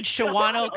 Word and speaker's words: Shawano: I said Shawano County.